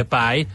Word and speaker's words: pi: Hát azzal szoktak, a pályjal szokták, mert angolul a kiejtése ugye pály.